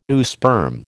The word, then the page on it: oosperm: American English